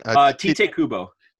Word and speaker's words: Tite Kubo: Tite Kubo. – Tite Kubo.